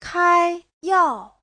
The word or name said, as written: kai1yao4